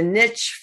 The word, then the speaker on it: niche: It's a niche field. Is that the right pronunciation? And it's not a niche. – I say field. – Niche. Some people say niche. – Niche. Okay. It's a niche field.